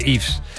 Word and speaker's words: Yves: Hoy es San Yves, como Yves Saint Laurent, como se pronuncia Yves.